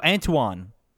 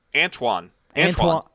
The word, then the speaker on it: Antoine: Can you tell me how to say that name? – Oh, the Antetion? – Antunian? – Antunian? Antoine. – Antoine. – Antoine. It's spelled really fucking weird, though.